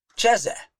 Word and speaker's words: chazeh: And they often say that that word is Cheze, C-H-A-Z-E-H in our alphabet. But there's another word they say is Chaza, C-H-A-Z-A-H, spelled exactly the same way in the Hebrew.